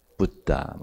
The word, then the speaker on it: Buddha: 但是巴利梵语的佛是重纯音 Buddha 嘛，哦，你去南部问那一些科佛像的，他们佛怎么念？